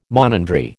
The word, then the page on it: monandry: American English